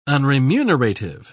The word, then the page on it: unremunerative: British English